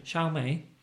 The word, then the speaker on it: Xiaomi: This is Xiaomi. It's called the Xiaomi Mi Band 3. – Xiaomi? – Yeah, that's the one.